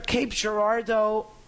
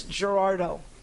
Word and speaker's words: Cape Girardeau: It's either Cape Girardeau, or I think that's how it's pronounced. I was saying Cape Girardeau, but I think it's Girardeau.